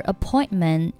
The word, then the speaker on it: appointment: 这里 is 和 it 连读成 is it, is it.Is it 末尾清辅音 t 不用发音 Is it possible to reschedule our appointment? Appointment 末尾 t 不用发音